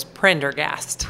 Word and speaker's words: Prendergast: Real quick, would you pronounce your last name so I don't destroy it for anyone? – Yes, of course. Prendergast. – Prendergast. – Yes. – All right. Erin Prendergast. So, did I say it right? – No. – I didn't. I heard it. One more time. – There's only one R. – Yep, that's right. – And it's at the beginning.